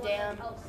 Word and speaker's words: dam: His mom is a dam. Note to self, I'm not swearing. It's spelled D-A-M. There's no N.